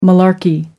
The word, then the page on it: malarkey: American English